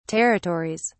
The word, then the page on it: territories: British English